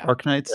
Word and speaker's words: Arknights: I hope I pronounced this right, Arknights. Is that correct? – Yeah. – Arknights? – Yeah. – All right. I know normally I've said Arknights, but Arknights. So we got a CG trailer last year for an Arknights Endfield.